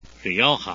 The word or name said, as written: rioja